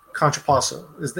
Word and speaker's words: contrapposto: Okay, so what we know, what makes the dynamic pose is contortion and contrapposto.